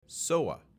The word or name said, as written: SOA